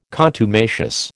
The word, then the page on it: contumacious: American English